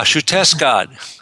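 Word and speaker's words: Xiuhtezcatl: One of the youngsters is Shuteskat. Shuteskat. Starts with an X, so it's a little hard to pronounce.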